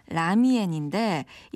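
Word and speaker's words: 라미엔 (拉面): 그러니까 중국 발음은 라미엔인데 이 남면이 밀가루 반죽을 손으로 잡아서 늘리면서 만드는 국수를 말하거든요.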